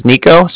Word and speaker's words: Niko: And then, again, my name is Nico, so that's spelled N-I-K-O.